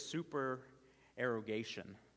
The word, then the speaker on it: supererogation: Um, and I probably won't say it right, but the word is supererogation. So it's the word super, E R O G A T I O N.